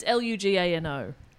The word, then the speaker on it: Lugano: It's Lugano. – Lugano. Lugano. – Yeah, perfect. – With an Italian accent. That's how you say it.